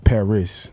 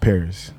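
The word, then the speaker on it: Parris: Girl, if you talk to my, you know what I'm saying, my mom, you talk to Tiff, like, it's Paris, not Paris.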